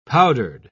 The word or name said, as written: powdered